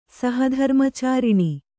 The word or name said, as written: ಸಹಧರ್ಮಚಾರಿಣಿ